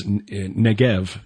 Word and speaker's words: Negeb: which to us English speakers is pronounced Negev, and we see it spelled N-E-G-E-V, but often in scripture we see it as Negeb. – Yeah. – But the B is pronounced as a V.